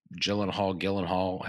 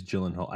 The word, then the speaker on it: Gyllenhaal: Is it Gyllenhaal? – I think it is Gyllenhaal. I think it is. I could be wrong. – I think it is. And I know I've heard it pronounced other ways, but I think it's Gyllenhaal.